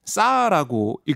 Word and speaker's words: SAR: 영상 레이더 아, SAR 싸라고 읽더라고요.